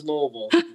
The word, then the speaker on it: Louisville: It's just Louisville. – DeVille. – Yeah, two syllables. Louisville. – Oh, because I said Louisville.